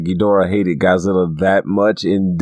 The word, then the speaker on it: Ghidorah: I'm probably butchering it. Ghidorah? For Ghidorah? – Yeah, Ghidorah, yeah.